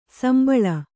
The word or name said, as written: ಸುಂಬುಳ